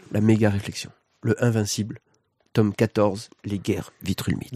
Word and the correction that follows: Viltrumite: Les guerres Viltrumite. Tu tu prononces très mal.